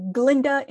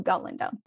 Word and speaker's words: Galinda: But after that, at class, Galinda is snarky towards Professor Dillamond, who is a goat, and he's not able to pronounce her name. He calls her Glinda instead of Galinda.